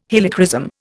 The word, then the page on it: Helichrysum: Indian